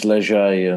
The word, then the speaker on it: Léger: It's Léger Holidays in the UK, but it looks like the French Léger, and in Dutch it means army, which is appropriate.